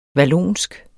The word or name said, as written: vallonsk